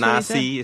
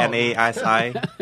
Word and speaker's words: nasi: So it's That's nasi. It's like N A S I, nasi.